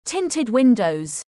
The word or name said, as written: Tinted windows